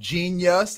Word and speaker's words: Jeen-yuhs: Donda is the name of his mother, D-O-N-D-A, Donda West. Okay. He has a documentary out right now on Netflix called Genius. It's spelled J-E-E-N-Y-U-H-S, Genius.